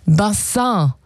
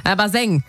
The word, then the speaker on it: Bassin: Ja, es kommt eigentlich aus dem Französischen. Bassin. Und bedeutet Becken oder Wasserbecken. Das kam so, das kam so im 18., 19. Jahrhundert hier rüber nach Deutschland. Wie viele andere Begriffe aus dem Französischen damals. Und naja, die Aussprache, die hat ein bisschen gehapert. Also statt Bassin wurde es irgendwann Baseng.